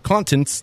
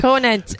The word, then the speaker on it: Conant: Nina then moved to Miami, where she continued to work in leading restaurants and hotels, including Scott Contents. Did I say that correctly? – Conant.